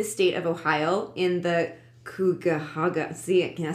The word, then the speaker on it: Cuyahoga: See, say that. – Cuyahoga. – Cuyahoga. – Yeah. – Sure. – It's spelled like that. C-U-Y-Hoga.